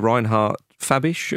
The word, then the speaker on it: Reinhard Fabisch: Their manager, Reinhard Fabisch, am I pronouncing that correctly? Yeah.